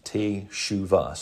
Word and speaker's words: teshuva: The Hebrew word for that is teshuva. It's pronounced teshuva, so it's teshuva.